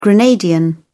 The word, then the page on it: grenadian: British English